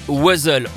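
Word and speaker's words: Wuzzles: Mais en France, on prononce les Wazzles, on ne prononce pas le S à la fin.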